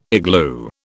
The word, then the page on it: igloo: British English